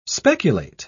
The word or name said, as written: speculate